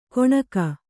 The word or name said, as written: ಗೊಣಗ